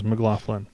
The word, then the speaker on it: McLaughlin: But uh, Mahavishnu Orchestra was uh, John McLaughlin. McLaugh, McLaugh, McLaugh. I shouldn't remember how to pronounce it. The guy from the McLaughlin group? McLaugh. – Yeah, it is McLaughlin. Sorry.